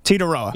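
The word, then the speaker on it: Tetairoa: So the I is silent. – Yes, Teetaroa.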